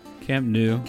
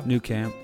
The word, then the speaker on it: Camp Nou: I don't know how you pronounce it, but Camp Nou. – Camp Nou. – New Camp. – Yeah. – Yeah. Either one.